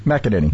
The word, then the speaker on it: McEnany: Neil Cavuto, during his show, was carrying the press conference from McEnany. I never get her name right. – McEnany.